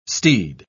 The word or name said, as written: steed